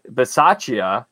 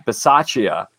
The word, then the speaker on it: Bisaccia: Las Vegas Raiders led by head coach, interim head coach Rich Biasia, Bisaccia. That's my, that's what I believe the Italian pronunciation of that would be. Uh, Rich Bisaccia, I think. But there's probably an Americanized version that people are going to tell me that I'm saying it wrong.